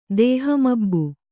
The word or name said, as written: ದೇಹಮಬ್ಬು